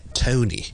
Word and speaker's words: Tony: 大家脚趾 Toe，我哋读 toe 得唔得？toe 好啦，Tony 咧，我哋咧先读 toe，再读 knee 即系边个咧？即系好似我哋读膝头哥个 knee Tony, and then we'll put them together and we'll get Tony. Tony. Okay? 就不是呢我们很多一般香港人会将它读成 Tony. 一通了就变成另一个音了。不是通是 toe, 加个 N 在后面就变成 tone.